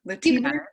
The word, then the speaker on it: Latinx: How do you say that? I've asked you before. Latinx. – Yeah, you got it.